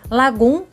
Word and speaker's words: lagom: Lagum se escreve L-A-G-O-M.